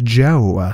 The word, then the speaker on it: DJO: Basic being basic, that is Joe, spell it D-J-O, but off the stage, you can call him J-O-E.